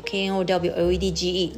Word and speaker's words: knowledge: 好，像是 k n 在一起，k n o w k n o w l e d g e knowledge，它只会发 n 的声音。